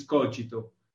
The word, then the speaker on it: Cogito: The right, it's Latin. So the right pronunciation is Cogito.